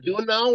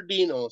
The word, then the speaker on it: Duenow: You can say it Duenow or Dino.